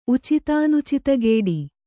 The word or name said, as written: ಉಚಿತಾನುಚಿತಗೇಡಿ